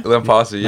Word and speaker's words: Lampasi: Lampasi, yeah.